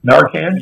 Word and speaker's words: Narcan: And a police officer collapsed that way and had to be Narconed because... – Narcan? – Yeah, Narcan.